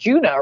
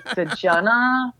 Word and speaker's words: Djunah: And then when I saw him, and he was like, "It's pronounced Djunah, right?" And I was like, "Yeah, okay. Like people will get this."